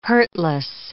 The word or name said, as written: hurtless